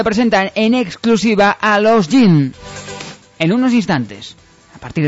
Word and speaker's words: Jin: bajo el nombre de Jin, J-I-N, Jin, pronunciado así en japonés.